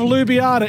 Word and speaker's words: Ljubljana: I think Ljubljana would be L-U-B-I-A-N-A. – Not even close. – Oh, come on. Ljubljana. – The beautiful city of Ljubljana is spelt L-J-U-B-L-J-A-N-A. – One of those with a silent J.